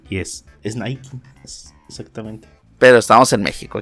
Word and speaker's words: Nike: ¿Nike o Nike? Ya, yo digo Nike ya. – Yo Nike, pero creo que es Nike, ¿no? – Es Nike.